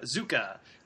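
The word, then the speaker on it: Kubozuka: And this movie stars Andrew Garfield, Adam Driver, Liam Neeson, Tadanobu Asano, Kieran Hines, Issei Ogata, Shinya Tsukamoto, Yoshi Oida, and Yosuke Kubuzaka. Zuka. I almost made it through all of those without messing up. – You were so close. – Almost. So close. – Last syllable. – The last one.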